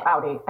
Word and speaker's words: Audi: Audi? How do you say it? – No, Audi. I go with Audi.